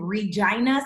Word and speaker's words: Regina: I was raised in Regina, Saskatchewan. And yes, I said the name right.